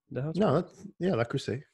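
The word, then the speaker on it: Le Creuset: I had a very fancy, I'm going to say this incorrectly, La Crusette. – La Crusade. Yeah. La Crusade. – There you go.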